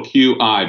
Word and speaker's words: qi: It's capital Q-I, but it's pronounced qi, like C-H-I.